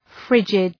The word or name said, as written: frigid